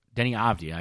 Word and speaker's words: Deni Avdija: Uh, next up, you have Denny of Dia, Deni Avdija, I should say, make sure pronouncing it right, uh, did end up going to the Bulls, which makes sense.